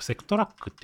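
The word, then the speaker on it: SEQTRAK: セクなんなんて読むんですかセクトラックって読むんですかうん。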